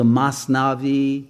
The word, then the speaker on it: Masnavi: Sometimes you'll see it called the Masnavi, other times the Matnawi. I think they have a Sephardic Ashkenazi split in Persian as well as in Hebrew, but you can pronounce it either way.